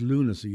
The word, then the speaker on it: Lughnasa: And he, Luganza, he called it. And he said, and everybody started to laugh. And he said, what have I done? I said, it's lunacy. You don't pronounce the G.